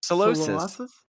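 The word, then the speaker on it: Solosis: Solossus. Is that how you say it? – Solossus.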